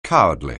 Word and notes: cowardly: anglais

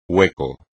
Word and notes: hueco: espagnol

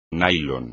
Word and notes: nylon®: espagnol

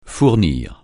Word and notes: fournir: francais

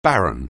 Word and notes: baron: anglais

